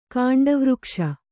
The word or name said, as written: ಕಾಂಡವೃಕ್ಷ